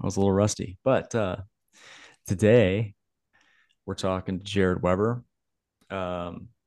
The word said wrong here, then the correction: Weber: Weber, actually. Weber, excuse me we we didn't spell it wrong, but I pronounced it wrong.